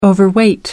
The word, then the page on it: overweight: American English